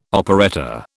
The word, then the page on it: operetta: British English